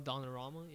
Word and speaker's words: Donnarumma: Donnarumma. How do you say him? The goalkeeper? – Oh, Donnarumma, yeah.